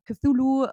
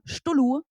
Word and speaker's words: Cthulhu: Ich muss nämlich ehrlich gesagt sagen, ich habe Cthulhu jahrelang Stullu ausgesprochen, weil ich nicht besser wusste.